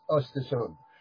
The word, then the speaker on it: Östersund: It's difficult to pronounce it if you're not Swedish, but Östersund.